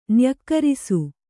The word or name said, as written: ನ್ಯಕ್ಕರಿಸು